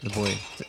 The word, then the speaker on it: bois: B-O-I-S. – Yeah. – The boy. The S is silent. We are in France.